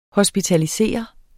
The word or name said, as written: HOSPITALISERE